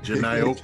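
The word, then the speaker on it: Jhené: I'm going to pronounce her name, Janine. – Janae Okay.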